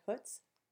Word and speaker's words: Hutz: Let's talk about Eugene Hutz first. Who is he? You tell us, because he's yours more than mine. – Eugene Hutz is the, I think I'm saying his name right, Hutz? – It's Hutz.